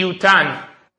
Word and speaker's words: yutan: On the other hand, the way that it's spelled in the Torah is itain.